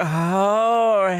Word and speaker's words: Jorge: Gonna be like, Jorge. – Emphasis on the whore. – What? No, Jason, don't be saying that. That's not what I meant. I just meant you got to pronounce it like, oh, hey.